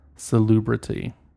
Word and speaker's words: salubrity: Its 6 points symbolize transportation, labor, commerce, finance, populousness, and salubrity. What does that mean? Hold on. – Are you saying that word right? – Salubrity.